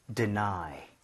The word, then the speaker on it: deny: 자, 원래는 D에 같은 느낌인데 뒤쪽에 조금 강세가면서 deny 앞에가 조금 흐트러지는 발음인 것 뿐입니다.